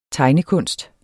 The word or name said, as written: tegnekunst